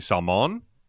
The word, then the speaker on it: Salman: You know, you can call me Salmon. He's like, oh, it's the emphasis is on the second.